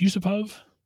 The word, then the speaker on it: Yusupov: So there was a plot to murder Rasputin, and I'm going to butcher this, I'm sure. Felix Yusupov? – That sounds pretty close, yeah.